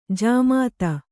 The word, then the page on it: ಜಾಮಾತ: jāmāta